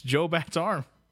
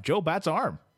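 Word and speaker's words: Joe Batt's Arm: It's Joe Bat's arm. – What? – Apparently. – Spell it. – J-O-E space V-A-T-T apostrophe S space A-R-M. Joe Bat's arm.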